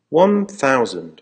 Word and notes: one thousand: American English

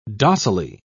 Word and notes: docilely: British English

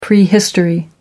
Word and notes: prehistory: American English

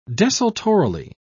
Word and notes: desultorily: British English

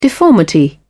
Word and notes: deformity: British English

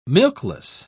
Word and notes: milkless: British English

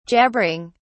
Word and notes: jabbering: British English

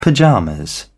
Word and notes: pyjamas: British English

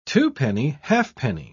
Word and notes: twopenny-halfpenny: British English

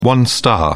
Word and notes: one star: British English

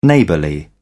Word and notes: neighbourly: British English

